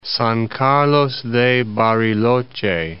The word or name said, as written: San Carlos de Bariloche